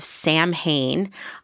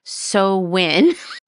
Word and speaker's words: Samhain: So it all starts with the ancient Celtic ritual of Samhain, although actually I just pronounced it wrong because it's really Samhain.